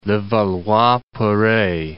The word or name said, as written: Levallois-Perret